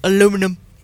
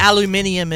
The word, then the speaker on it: aluminium: Aluminum. – It is gold. – Oh, gold. – Wow. No, but aluminium is how British people pronounce aluminium. – They're wrong.